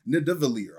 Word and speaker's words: Nidavellir: And this gauntlet was forged in the furnaces of Nidavellir. I hope I didn't pronounce that wrong. – No, you nailed it.